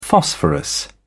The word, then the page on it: phosphorus: British English